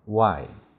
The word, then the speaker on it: why: 还有个为什么 why？ WH 要发 w，也就是 H 不发音。 Y 要发长音 I。